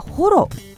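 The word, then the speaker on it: 母衣: 平家物語に登場する源氏の武将熊谷次郎直実という人物にちなんでいまして、由来は彼が背負っていた弓矢を避ける背中に背負っていたホロ、こちらは母に衣類の衣と書いてホロと読むんですけれども、そちらに見立てて付けられた名前ということなんですね。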